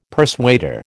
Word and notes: persuader: American English

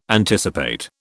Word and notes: anticipate: British English

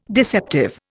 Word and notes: deceptive: Indian

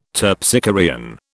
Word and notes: Terpsichorean: British English

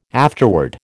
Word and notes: afterword: American English

